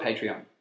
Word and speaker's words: Patreon: It's called Patreon.